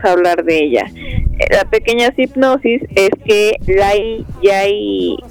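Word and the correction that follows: Lai Yiu-fai: ay no, perdón, perdone mi pronunciación, o sea, chanclazo. Lai Yufai y Hopo Win, pues ellos viven en Hong Kong, tienen una relación tormentosa, abusiva.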